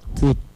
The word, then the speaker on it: jut: Que en Yucatán se dice jut. – Jut.